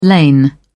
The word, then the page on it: lain: British English